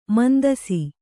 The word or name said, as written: ಮುಂದೆಸೆ